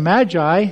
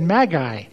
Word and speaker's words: magi: Instead of magi, she said magi. That's actually much closer to the Greek. A hard G instead of a soft G.